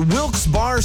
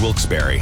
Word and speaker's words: Wilkes-Barre: Now, I had heard that it was supposed to be pronounced Wilkes-Barre. Is that true? Wilkes-Barre Scranton's 97.9X. The only way to pronounce Wilkes-Barre. – I disagree. I think it's Wilkes-Barre, Dexter.